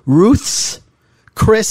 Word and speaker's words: Ruth's Chris: So in reverse order, on Friday night, so I don't know, 6 days ago, we went to, saying it properly, Ruth's Chris Steakhouse. – Correct. – That's Ruth apostrophe S.